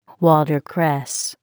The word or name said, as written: watercress